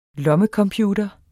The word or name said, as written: lommecomputer